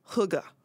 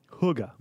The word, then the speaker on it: hygge: And it's the best pronunciation I found is hygge. – Hygge. – Hygge. – Well, hug.